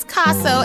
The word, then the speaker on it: Caso: Pronounce Caso as in the Picasso.